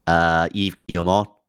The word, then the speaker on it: Yves Guillemot: Yves Guillemot.